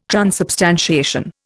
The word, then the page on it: transubstantiation: Indian